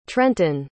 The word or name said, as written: trentin